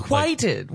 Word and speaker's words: requited: It's requited. Like, why are you saying quiet? – How do you say it? – Requited love.